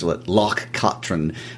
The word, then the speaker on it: Loch Katrine: And I would like to point out that pronunciation was taught to us by our good friends Hamish and Nancy in Scotland. – Yes, we were mispronouncing it for a while. – They corrected us. Loch Katrine.